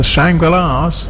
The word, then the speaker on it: Shangri-Las: Well, Sarah says it's pronounced the Shangri-Las. – It is.